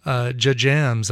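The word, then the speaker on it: JJAMZ: She's part of a great band called J-Jams, I think is what it's, how it's pronounced. J-J-A-M-Z, a band that's made up of the first initials of all the band's members.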